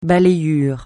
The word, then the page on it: balayures: francais